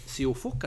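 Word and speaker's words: Siófok: Og han har faktisk nå, etter veldig mange år i Larvik, bestemt seg for å reise ut da til ungarske Siofok. Er det sånn du uttaler det? – Ja.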